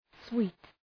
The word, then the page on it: sweet: swi:t